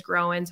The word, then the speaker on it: Groen: And that's kind of a fun little pun, because my maiden name is Growin. So it's spelled Groen.